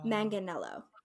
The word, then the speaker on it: Manganiello: I want to make sure I say his name right. I looked it up because I wanted to make sure. So it's Joe Manganiello.